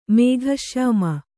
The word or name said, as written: ಮೇಘಶ್ಯಾಮ